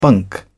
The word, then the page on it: bunk: British English